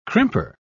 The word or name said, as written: crimper